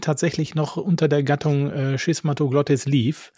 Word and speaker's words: Schismatoglottis: Denn die Schismatoglottis, wird der Name so ausgesprochen? Ja, doch. Ja. Schismatoglottis, genau.